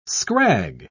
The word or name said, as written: scrag